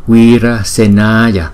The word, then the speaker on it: Wirasenaya: Tinh tấn quân Phật chúng ta đọc là Wirasenaya, Wirasenaya, Wirasenaya. Thấy không? Chữ Y có dấu gạch ngang, chúng ta cũng đọc hai nhịp. So với chữ Y bình thường thì đọc một nhịp.